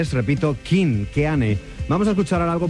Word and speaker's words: Keane: 97, Tom Chaplin a las voces, Tim Rice al piano y la batería Richard Hughes, se llaman King. La canción es bella como pocas, sin duda. Se llama Somewhere Only We Know y el grupo lleva por nombre King. Se escribe Keane con K.